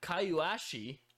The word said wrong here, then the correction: Kuyashi: I think it's Kuyashi.